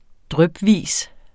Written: drypvis

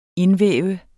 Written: indvæve

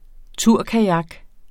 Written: turkajak

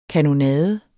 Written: kanonade

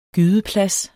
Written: gydeplads

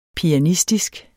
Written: pianistisk

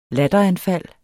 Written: latteranfald

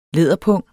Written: læderpung

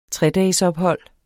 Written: tredagesophold

